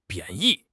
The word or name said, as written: 贬义